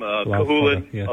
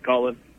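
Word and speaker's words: Cú Chulainn: Because I've just recently gotten into reading, and I've read a number of books about Lu Lomfada, obviously Louis the Long Arm, Cahulain, otherwise sometimes it's pronounced Cahulain.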